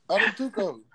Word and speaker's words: Antetokounmpo: N-T-I-L-I-K-I-N-A-S. – How you say Giannis' last name? – Antetokounmpo.